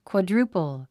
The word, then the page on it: quadruple: kwɑdrúːpl; クアドゥルゥポォ